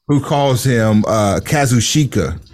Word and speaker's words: Kazuchika: And I never try to pronounce his first name, so I get it's Kazuchika. That's not that hard.